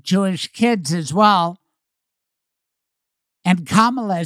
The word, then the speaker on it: Kamala: Not Kamala, not Kamala. It's Kamala, like Mamala or Bubala.